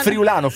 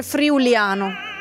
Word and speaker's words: friulano: Friulano. – No, l'errore di tutti è dire friuliano.